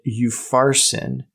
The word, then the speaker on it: Upharsin: In the KJV, it's spelled U-P-H-A-R-S-I-N. And when I was listening to it, they pronounced it eupharsin with the P-H, like the Greek P-H.